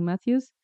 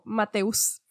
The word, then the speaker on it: Matthews: Em, cabe mencionar que yo estaba pronunciando el nombre con como Mateus. como que lo estaba leyendo muy literal, pero sí, si lo decimos en inglés es Matthews.